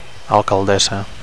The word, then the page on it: alcaldessa: Catalan